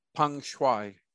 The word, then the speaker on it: Peng Shuai: You'll say her name for me, Peng Shuai.